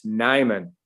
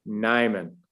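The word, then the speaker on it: Nijman: The J is silent. It's Nyman. Yosh Nyman.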